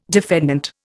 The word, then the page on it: defendant: Indian